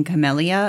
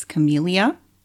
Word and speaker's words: Camellia: By the way, apparently I was saying it wrong. I have been saying Camellia. Apparently it's Camellia. Anyway,